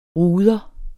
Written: ruder

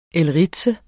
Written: elritse

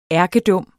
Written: ærkedum

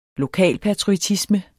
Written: lokalpatriotisme

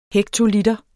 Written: hektoliter

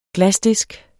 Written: glasdisk

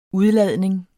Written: udladning

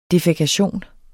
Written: defækation

